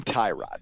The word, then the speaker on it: Tyrod: We call him Tyrod because that's how it's spelled. I mean, I know it sounds like a part of a car, but I can't do anything about that. That's what it looks like on paper.